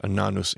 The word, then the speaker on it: Annas: And Ananus is how you say Annas. We say Annas in English, Ananus in Hebrew.